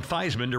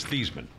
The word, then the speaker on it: Theismann: They called him Theismann to rhyme with Heisman when he was at Notre Dame. But, again, his name is the family name is Theismann.